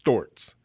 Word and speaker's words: Storz: We pronounce it in the U.S. stores, but I understand in Germany it's pronounced Storz, S-T-O-R-Z.